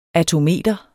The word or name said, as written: attometer